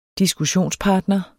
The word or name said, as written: diskussionspartner